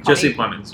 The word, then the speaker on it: Jesse Plemons: Jesse Plemminger? I'm probably saying it wrong. And then Amy Schumer did a hilarious... spoof on Friday Night Lights where she was the Connie. What's her name? – Jesse Plemons.